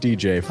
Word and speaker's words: Deja: Yes, our thanks to the great Tom DJ, whose name is spelled Deja, but pronounced DJ for reasons unexplained.